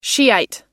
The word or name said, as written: shiite